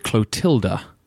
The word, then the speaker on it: Clotilda: Listener Mark points out that King Clovis of the Franks married Clotilda, and not Clotilde, as I pronounced it.